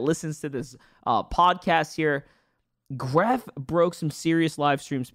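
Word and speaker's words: Grefg: The G is silent, so we call him Gref.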